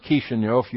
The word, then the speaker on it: Kishinev: But in Kishinev, right here, that is pronounced Kishinev. You'd never guess that. And it took me about 2 weeks to learn how to say it.